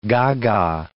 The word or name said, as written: gaga